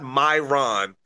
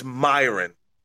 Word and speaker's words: Myron: Say it right. It's not Myron. It's Myron.